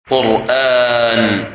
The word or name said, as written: Quran